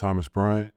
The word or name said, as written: Thomas Bryant